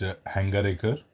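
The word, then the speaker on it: Hangargekar: is this Hangar Kika. Give me the proper pronunciation, because obviously I'm butchering it. The under 19 Seema. – It's Hangarikar.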